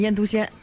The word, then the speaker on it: 腌笃鲜: 对，其实就应该念腌笃鲜啊。